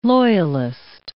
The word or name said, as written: loyalist